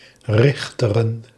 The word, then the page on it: Richteren: /ˈrɪxtərə(n)/; 3 lettergrepen; Rich·te·ren